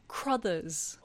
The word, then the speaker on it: Crothers: I thought we were saying Crothers is all. – Oh, is his name Crothers? – Yeah. – Oh, I'm sorry. – No, I just... – Well, I was saying Carruthers. – Oh, okay. – Scatman Carruthers. – Oh, I definitely heard Crothers. – That's just a pronunciation thing. That's not a... – Yeah. No, I like it. Either works.